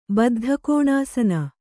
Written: ಬದ್ಧಕೋಣಾಸನ

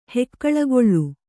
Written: ಹೇಕುಳಿಗೊಳ್

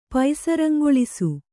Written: ಪೈಸರಂಗೊಳಿಸು

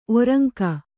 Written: ಒರುಂಕು

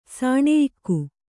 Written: ಸಾಣೆಯಿಕ್ಕು

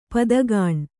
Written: ಪದಗಾಣ್

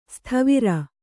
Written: ಸತ್ವರ

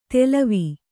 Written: ತೆಲವಿ

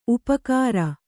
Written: ಉಪಕಾರ

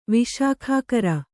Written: ವಿಶಾಖಾಕರ

